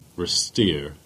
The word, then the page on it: Retire: Cornish